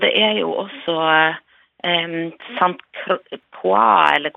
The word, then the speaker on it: Saint Croix: Ja, uh, si det en gang til, så skal jeg eventuelt korrigere det. – Sant Croix. – Saint Croix, ja. – Ja, var det Ja da, det er riktig uttalt.